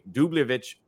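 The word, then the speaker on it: Dubljević: We're going to Montenegro to play Dubljevic under 11.5 points, -130 at Bet365.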